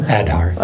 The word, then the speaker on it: Aadhaar: Aadhaar.